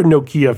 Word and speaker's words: Nokia: Yes, that would be another interesting one because Nokia Maps were always good. It's pronounced Nokia, by the way, not Nokia. No, the Americans always get it wrong.